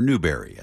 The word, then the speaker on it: Newbury: Why You Been Gone So Long, Gretchen Peters, from the night you wrote that song, Songs of Mickey Newbury, or Newberry, as we're more likely to pronounce it.